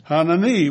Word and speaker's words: Hanani: Now, it happened in the month of Kislev, in the 20th year, as I was in Susa, or Shushan, the citadel, that Hanani, accent on the last syllable, you see, Hanani, one of my brothers, came with certain men from Judah.